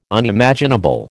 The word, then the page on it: Unimaginable: American English